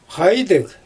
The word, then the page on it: xajdɯg: Xyzyl